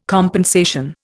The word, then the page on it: compensation: Indian